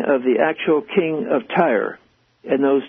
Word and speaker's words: Tyre: I was reading about the king of Tyre, I believe you pronounce it that way.